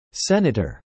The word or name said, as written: senator